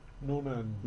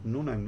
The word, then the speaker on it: Noonan: Noonan. – Noonan, ¿no? – Ajá.